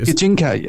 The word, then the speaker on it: Gijinka: Okay, so let's talk, and I'm going to probably butcher this, but Gijinka, I think is how you pronounce it. – Gijinka, yes.